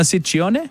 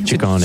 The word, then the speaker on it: Ciccone: Ciccone. – Ciccione. What is it, sir? – Well, in Italian, it works the other way. So the double C's are hard C's and the singular C's are soft C's.